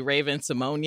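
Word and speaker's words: Raven-Symoné: Did you see recently she was like, everybody's been pronouncing my name wrong. – Yes. – And it's actually Raven Simone.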